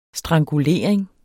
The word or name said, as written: strangulering